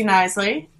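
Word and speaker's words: Knisley: Mine is just Lucy Nisley. My last name has a silent K in it at the beginning, like knife or knickerbocker.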